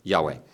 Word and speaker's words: Yahweh: How we would pronounce it, yeah. – Yahweh. – Yahweh.